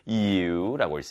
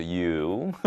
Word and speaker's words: ewe: So one day, 그래서 어느 날, after spending all morning trying to catch a naughty ewe, 자, 이거 발음 특이하죠. ewe라고 있습니다. ewe입니다. 그래서 ewe. 맞죠? 발음이 좀 특이합니다.